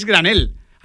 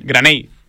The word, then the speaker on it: Granell: Es Alex Granell. – Graney. – No he pronunciado ni bien ni el nombre ni el apellido.